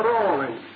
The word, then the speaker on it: Raleigh: Or, as they say, the correct pronunciation is Sir Walter Raleigh.